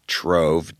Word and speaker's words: Trov: Trove, T-R-O-V. It could be pronounced Trove.